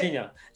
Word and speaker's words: Jairzinho: How do you say Jairzinho.